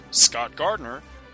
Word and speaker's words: Scott Gardner: You can find me, Scott Gardner, both on Twitter and Facebook. My name is spelled S-C-O-T-T. G-A-R-D-N-E-R.